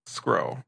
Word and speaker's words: Skrull: Skrull. The Skrull. – Spell that. – S-K-R-U-L-L.